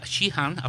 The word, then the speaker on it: Shihan: Sheehan, I'll spell that, Shihan. Can you just, before we start, what's Sheehan?